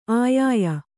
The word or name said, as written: ಅಯ್ಯಯ್ಯೊ